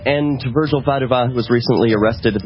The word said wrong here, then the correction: Vaduva: I am terrible about mispronouncing Virgil's last name. It's apparently Vaduva.